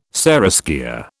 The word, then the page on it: seraskier: British English